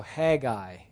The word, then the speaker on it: Haggai: So A-I, the ending diphthong there, the two vowels that make one sound, is like in the word aisle, A-I-S-L-E, like the center aisle of the church, I. So Haggai is the way to say that, just like the city that's spelled A-I in Joshua.